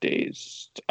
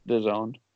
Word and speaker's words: DAZN: I thought it was called Dazed. I had no idea how to pronounce it. – I didn't know it was DAZN. – I was like, oh, it's DAZN.